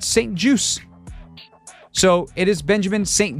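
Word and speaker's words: St-Juste: And that is that his name is said Benjamin St. Juice, as in a juice box, as in apple juice, orange juice. It is French-Canadian. Or Canadian French, more specifically. There's no T pronounced. This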